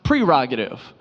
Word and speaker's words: prerogative: See, it's actually pronounced prerogative. That's your prerogative.